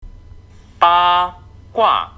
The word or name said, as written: ba gua